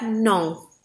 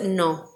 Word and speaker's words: Nantes: I'm pretty sure that's how you say it. – Okay. – Drownings at Nantes. – But what about the N and the T? – N-A-N-T-E-S. Yeah, but I looked it up.